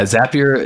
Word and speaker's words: Zapier: Yeah, Zapier is the correct way to pronounce it. We always say Zapier makes you happier.